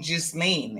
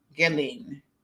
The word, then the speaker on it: Ghislaine: I was pronouncing the S. So I was saying it like, because it's spelled G-H-I-S-L-A-I-N-E. So I was saying Ghislaine, and it's actually Ghislaine. Gelin. So the S is completely silent.